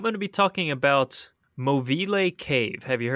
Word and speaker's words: Movile: It's spelled Movile, but it's pronounced Movile.